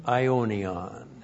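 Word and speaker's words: aionion: And then we have the outcome which is declared zoein ionion. Z, long O, long E, N, Zoein, that's the accusative case as a direct object. That's life. And then Ionion, A-I, long O, N-I-O-N, and that's eternal.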